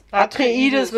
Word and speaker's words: Atreides: Heimatwelt von Haus Atreides oder so. Atreides wird's ausgesprochen.